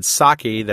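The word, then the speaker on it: psake: He created Psaki, that's P-S-A-K-E, silent P, a PowerShell-based build automation tool to save developers from XML hell.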